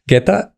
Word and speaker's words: Guetta: Eh, hay un DJ francés, espero pronunciarlo bien, se llama David Guerra o David Guerra, Guetta.